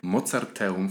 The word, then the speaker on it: Mozarteum: Jestem z Aleksandrą Gajecką, która jest doktorem sztuki muzycznej, ukończyła Akademię Muzyczną w Łodzi, Uniwersytet Mozarteum w Salzburgu, dobrze wymawiam? – Tak, tak, wszystko jest ok.